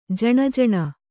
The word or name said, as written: ಜ್ಞಾನಿ